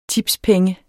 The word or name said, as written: tipspenge